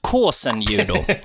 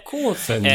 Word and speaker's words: kosen judo: eller kosen judo, uttalet är lite oklart. – Det finns någon fonetisk skrift här. – Kåsenjudo. Kåsenjudo. eh, och så som jag uppfattar det ser det lite ganska så eh, likt BJ, eller snarare BJ är förmodligen likt Kåsenjudo.